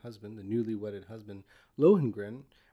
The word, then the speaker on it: Lohengrin: called Lohengrin. Is that how you pronounce it? – That's Lohengrin. How I would pronounce it.